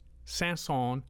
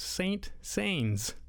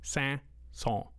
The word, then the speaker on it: Saint-Saëns: If you've never heard Saint-Saëns' pronounced before, maybe you're like me who went for years, I only saw it in print and I thought it was pronounced Saint Saëns, because that's what it looks like. I had no idea what French pronunciation was all about. I'm still a little hazy, but I do know it's pronounced Saint-Saëns. Say it with me just a few times.